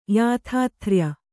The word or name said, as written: ಯಾಥಾಥ್ರ್ಯ